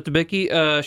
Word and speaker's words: Debicki: Is it Elizabeth Debicki? Is that how you pronounce her name? – Mm-hmm. – Where have we seen her before? – Elizabeth Debicki, she was in, great phone.